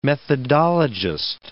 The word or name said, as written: methodologist